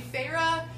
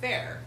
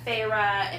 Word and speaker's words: Feyre: So I was like, oh, that's great, because before I was pronouncing it fair. – Mm. – And so I was like, oh, I'm pronouncing that wrong in my head.